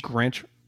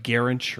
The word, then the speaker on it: Grinch: How do you say his guy's last name? Grinch? – Alex Grinch? – Grinch, yeah. Kind of getting defensive, like you guys were just waiting for a shitty spot. Well, I didn't know if it was like Garinch or Grinch. I felt like I was saying it wrong.